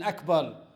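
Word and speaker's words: Akbar (أكبر): Also, one should not prolong pronouncing the letter Ba in Akbar by saying Allahu Akbar, because Allahu Akbar, that term will give a different meaning.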